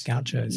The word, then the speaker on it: gauchos: A lot of gauchos. – Gauchos. – Yes, you pronounced it for me earlier. – Well, that was the Brazilian pronunciation.